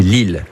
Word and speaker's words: Ill: C'est l'Ill. Ça s'écrit I2L.